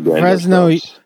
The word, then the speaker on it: Fresno: With a soft S. Fresno. – Yeah. Yeah, because you kind of get Fresno. Fresno has like a Z kind of hit. When you hit the S, you hit it with a Z kind of thing.